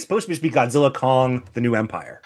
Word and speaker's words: Godzilla x Kong: I think it's supposed to be pronounced as Godzilla Kong or Godzilla Cross Kong because it uses the cross.